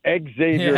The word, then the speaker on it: Xavier: If we're talking about the school in Cincinnati and all the Jesuit schools, they pronounce it as Xavier. – Yep. – But if we're going old school NBA from the 80s, the 90s, he was Xavier McDaniel because he was the X-Man. – And that's exactly what I was... – You can't be the X-Man if you don't pronounce the X.